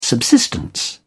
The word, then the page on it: subsistence: British English